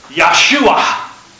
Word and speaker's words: Yahshua: In Hebrew, they don't pronounce a J as a J. It's Yah. Okay. So in Hebrew, Moses changed his name to this. Yahshua.